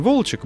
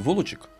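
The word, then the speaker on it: Волочёк: И вот как раз Вышний Волочек был одним из населенных пунктов. – А вы сейчас правильно говорите «Волочек»? – Потому что Вышний Волочек, Волок. Потому что Волок.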